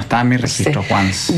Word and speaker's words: Once: Se escribe Once, como en inglés Once. – Como Once Upon a Time.